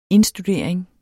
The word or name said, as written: indstudering